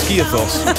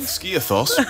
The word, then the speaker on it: Skiathos: Oh, you say it's Skiathos, by the way. There's one for all of you. Little glimpse behind the scenes there. We love to do that. We lift the curtain. Little pronunciation guide in real time.